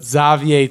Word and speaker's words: Xavier: Not Xavier Tillman, but Xavier Tillman. – People pronounce it Xavier? – He's French. He's French.